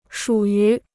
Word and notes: 属于: shǔ yú

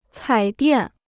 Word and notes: 彩电: cǎi diàn

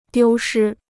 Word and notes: 丢失: diū shī

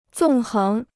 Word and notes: 纵横: zòng héng

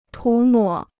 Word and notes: 涂抹: tú mǒ

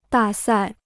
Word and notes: 大赛: dà sài

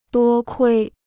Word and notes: 多亏: duō kuī

